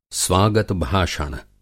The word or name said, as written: स्वागत-भाषण